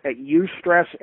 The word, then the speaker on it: eustress: But you did mention, and I was interested in this, good stress is called eustress. Is that pronounced correctly? – Yes, it is. It's eustress, and it's spelled E-U-stress.